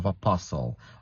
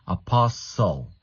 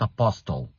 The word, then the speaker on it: apostle: So you see, you have a letter T there, but usually when you have an S and another consonant afterwards, you don't pronounce the T. So you say apostle. Listen, right? It's not apostle. It's not a list 10. No, no, no, no, no, no. It's apostle, apostle, listen, listen. Very good. The T is silent.